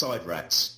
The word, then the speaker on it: SIDRATs: And you've got other time machines, and it's all called, it's spelled Sidrats, but pronounced Sidrats, which is based on the inversion of the word TARDIS.